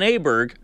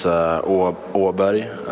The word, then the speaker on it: Åberg: because we've settled on Aberg, but as he's going to tell you, we're all saying it wrong. – Many different ways apparently to say it. No one really knows, but in Swedish it's Åberg, and that's it's a Swedish letter, the first one, so it doesn't exist over here, so it's hard for you guys to say.